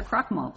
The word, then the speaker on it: Krockmal: Your Honor, it's pronounced Crockmull as if it were K-R-O-C-K-M-A-L. – Mr. Crockmull, please proceed.